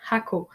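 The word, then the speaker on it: Jacó: Ça se prononce en espagnol Jaco, mais Jaco, J-A-C-O, euh, sur la côte pacifique.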